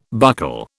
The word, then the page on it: buckle: British English